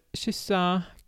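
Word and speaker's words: kyssa: Oder mit Y, dann wird es halt Shisa, Küssen, Shirka, Kirche.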